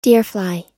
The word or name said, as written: deerfly